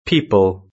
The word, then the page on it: people: British English